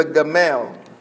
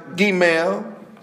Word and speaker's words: Gimel: And so without reviewing that, I want to go now to the third letter of the Hebrew alphabet, and it is the Gamel. And it's sometimes pronounced Gimel and carries with it the sound of G, as in girl.